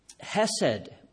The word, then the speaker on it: hesed: It's a word, hesed. I don't have it on the screen. It's spelt in the English, if you like, H-E-S-E-D, hesed.